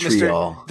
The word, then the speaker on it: vitriol: Did I say that right? – Vitriol. – Vitriol. Thank you.